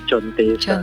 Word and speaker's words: Contessa: Soalnya kan karena bahasa Indonesia pakai C, jadi bacanya jadi Emilia Contessa.